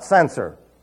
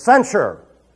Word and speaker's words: censure: No, it's not censor. It's censure. It's sure on the end. There's a shusher there.